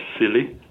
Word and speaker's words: SILI: SILI, pronounced as silly.